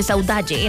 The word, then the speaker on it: saudade: Y encontré una palabra que se escribe saudade, pero se pronuncia saudade en el portugués.